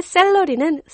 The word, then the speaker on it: celery: C-E-L-E-R-Y. Salary와 발음이 좀 비슷한 것 같은데 어떻게 다르죠? – Yeah, you're right. You know, 월급은 salary, salary라고 하고요. 야채, celery는 celery, celery로 발음됩니다.